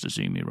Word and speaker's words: Izumi: So I pronounced it AZM as well. And Chris had to go, whoa, It's pronounced Izumi. Don't be an idiot. It's Izumi.